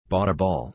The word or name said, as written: bought a ball